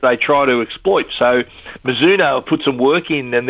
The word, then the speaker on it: Mizuno: This is Mizuno.